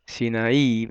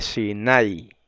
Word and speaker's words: Sinaí: Quiero, aunque no es muy importante, pero quiero recalcar que la palabra Sinaí, usted sabe que estamos acostumbrados a decir Sinaí, Sinaí, ¿verdad? Por eso viene del griego. En griego se puso así el Sinaí, con acento en la I. Pero en el hebreo se acentúa en la A, es Sinaí.